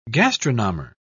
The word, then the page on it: gastronomer: British English